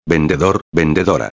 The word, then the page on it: vendedor/a: espanhol